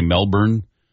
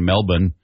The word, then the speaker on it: Melbourne: Now, usually we say Melbourne and then sometimes say Melbourne, but they weren't even pronouncing the R's during the broadcast last night.